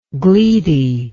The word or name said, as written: gleety